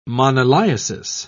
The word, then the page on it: moniliasis: British English